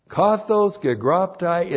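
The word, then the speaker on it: Kathos gegraptai (καθὼς γέγραπται): Kathos. Gegraptai. That's exactly how it looks in the Koine Greek. K-A-T-H long O-S G-E-G-R-A-P-T-A-I.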